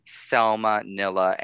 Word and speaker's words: Selma Nilla: So I'm on Instagram, Twitter, and TikTok under Selma Nilla NYC. It's Selma, like Selma Blair, Nilla, like the wafer, NYC.